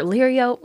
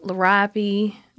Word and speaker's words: Liriope: But first, before we talk to you, I have a question, which is, how many ways have you heard Liriope pronounced in your life? – Wow, I've heard Liriope, Liriope, Liriope.